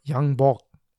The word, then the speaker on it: Yang Bok: In the autumn, Yang Pu, or Yang Bok, as it's pronounced in Korean,